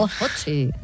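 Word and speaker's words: Ötzi: Otzi, creo que se llamaba, un nombre muy, muy noruego. – Utsi, ¿no? – No sé, lo pronuncias tú mejor que yo. – Utsi, ah, vale.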